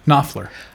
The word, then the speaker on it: Knopfler: Knopfler.